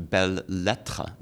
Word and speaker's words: belles lettres: Belletristic comes from belle lettre, belle letters. – Oh. – Belle lettre, B-E-L-L-E-S. L-E-T-T-R-E-S. Belles lettres means beautiful letters, literature.